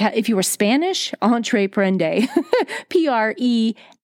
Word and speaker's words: entreprendre: Entreprendre. – Entreprendre. – Entreprendre. – Entreprendre. I don't have it. Okay, but that is the original pronunciation from the French word for entrepreneur,